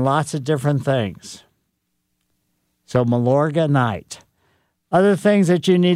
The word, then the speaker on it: Milorganite: Milorganite. So it's like Milandromat.